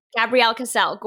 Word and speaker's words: Gabrielle Kassel: So it kind of rhymes. – Gabrielle Kassel.